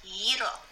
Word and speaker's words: gyro: It's called gyro. That's how you pronounce it.